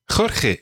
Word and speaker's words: Jorge: Jorge, exactly. Yeah, it's a really tricky name, but it's a good name to practice the ch sound of Spanish. Se llama Jorge.